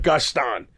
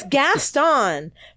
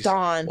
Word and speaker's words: Gaston: Okay, read a book like Belle and I'll shove it in the mud like Gaston. But it's Gaston. Fucking pronounce the name right. – Oh, please. – Gaston.